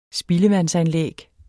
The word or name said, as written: spildevandsanlæg